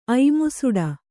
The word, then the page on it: ಐಮುಸುಡ: Kannada